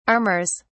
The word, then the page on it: armours: British English